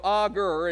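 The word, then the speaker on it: Agur: Now, I try to remember how to say it because it sounds kind of like author. That's a name, art, you know, author. I just go Augur and just kind of take out the th sound.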